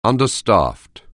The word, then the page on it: understaffed: anglais